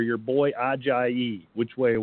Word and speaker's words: Ajayi: Ajayi. – You have to say it properly. – Ajayi. – There you go.